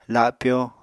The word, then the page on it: lapyo: apatani